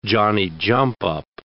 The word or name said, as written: Johnny-jump-up